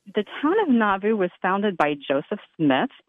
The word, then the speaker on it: Nauvoo: And, you know, you helped me out, too, because I was going to have to ask you how to pronounce the town, and you said it's Nauvoo. – Nauvoo, yeah.